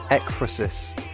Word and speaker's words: Ekphrasis: It's called Ekphrasis. That's how you pronounce the word Ekphrasis.